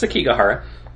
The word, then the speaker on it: Sekigahara: I'll let one of you two Sekigahara. – Fuck up the pronunciation on it, so. – That's Sekigahara.